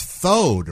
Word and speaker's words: throwed: It's thowed, right? The R is not pronounced? – Get thowed. – Yes.